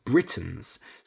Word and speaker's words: Britons: For example, in the UK, the Celts were called Britons, spelt B-R-I-T-O-N. Confusingly, it's pronounced the same as the name of the country, Britain.